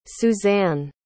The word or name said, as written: Susanne